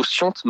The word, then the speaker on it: Scient: Pourquoi ce nom pour votre association, Sian? Ça s'écrit S-C-I-E-N-T. – C'est ça, Sian. Donc en fait, c'est plutôt Siante même.